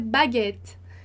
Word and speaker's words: baguette: Um exemplo muito bom para você lembrar dessa regrinha é a palavra baguette, né? Então, baguette tem os dois T's. Porque tem os dois T's, a gente vai pronunciar o E anterior com o som de E. Baguette, baguette, tá?